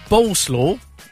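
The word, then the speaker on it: Bolesław: Boleslaw, which is like coleslaw.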